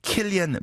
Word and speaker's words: Cillian: 예를 들어서 Saurus로는 S-A-O-I-R-S-E-R-O-N-A-N 이 왜 Saurus인지 그 다음에 Killian Murphy C-I-L-L-I-A-N Murphy가 왜 Killian Murphy인지 Cillian이 아니고 말이죠.